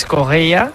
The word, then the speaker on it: Correia: A pronunciarlo bien, Stassin, como nos han apuntado, y Félix Correia, como dice José, con muy buena pronunciación portuguesa.